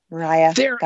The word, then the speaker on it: Mariah: Mariah.